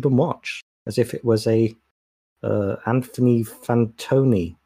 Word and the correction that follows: Fantano: Is that how you pronounce his name? – Fantano, yeah.